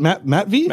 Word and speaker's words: Matvey: Can't figure out if it's for Will Smith, who's getting jiggy with it, or Matvey? Is that how you say it? Matvey Michkov? – Yeah. – Matvey Michkov. Can't even figure out who it is at number 3 pick.